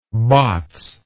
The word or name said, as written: bots